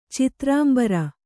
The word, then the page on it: ಚಿತ್ರಾಂಬರ: citrāmbara